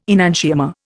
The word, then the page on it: enantiomer: Indian